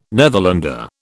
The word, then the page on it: Netherlander: British English